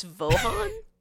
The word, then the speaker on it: Vaughan: It's spelled V-A-U-G-H-A-N, so I pronounce it as Vaughan. – We can go with that. I've been saying Vaughn, but I also don't know how Vaughn is spelled. So it looks, if you look at it quickly, it does look like Vaughn.